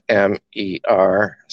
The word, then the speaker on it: Mercier: I think it's French. I think it's probably pronounced Mercier. It's Mercier. And he wrote a book called Invisible Contracts.